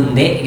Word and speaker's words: nde: Kalau misalnya Ranai, dialek Ranai ini banyak huruf E-nya, kalau bilang tidak itu nde gitu.